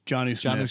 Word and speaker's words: Jonnu: Is it Jonnu? Is that how you say it? – Jonnu Smith. Yeah.